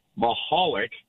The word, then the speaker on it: Michalek: And their last name was Michalek, M-I-C-H-A-L-E-K, right? Mm-hmm. Well, one of them pronounced it in San Jose, Michalek, and the other one, the brother in Arizona, pronounced it Michalek. And so you had two brothers who were even telling people to say their name differently.